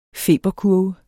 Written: feberkurve

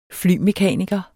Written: flymekaniker

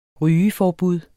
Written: rygeforbud

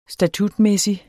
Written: statutmæssig

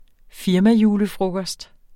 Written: firmajulefrokost

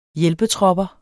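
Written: HJÆLPETROPPER

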